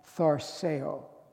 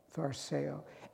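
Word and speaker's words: tharseo (θαρσέω): In Greek, it's pronounced tharseo, tharseo.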